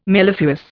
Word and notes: mellifluous: Indian

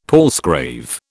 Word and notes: palsgrave: British English

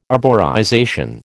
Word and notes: arborization: American English

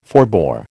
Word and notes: forbore: American English

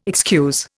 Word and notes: excuse: Indian

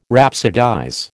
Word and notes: rhapsodize: American English

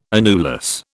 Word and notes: annulus: British English

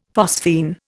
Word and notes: phosphene: Indian

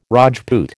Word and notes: Rajput: American English

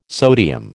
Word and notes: sodium: American English

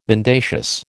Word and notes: mendacious: American English

